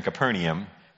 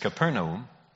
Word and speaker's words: Capernaum: Capernaum, and I know you're used to hearing Capernaum, but it's not pronounced Capernaum. It is Capernaum, is in Naphtali.